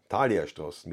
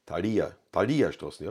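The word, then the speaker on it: Thaliastraße: Thalia-Straßen mit Betonung auf I. Thalia-Straßen, ja.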